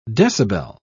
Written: decibel

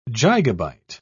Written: gigabyte

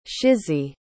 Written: shysie

